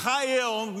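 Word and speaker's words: Chayil (חיל): Everyone say Isha and say, it looks like Chayil on here, but it's pronounced, this is a fun one to say, and that's why I'm letting you do it. Chayil. Everyone say Chayil.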